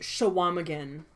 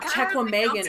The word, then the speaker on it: Chequamegon: It's pronounced Shawamagan. Thank you very much. Yes, I'm sure. That's where I grew up, Shum. It's Shawamagan. It's not Chequamegon.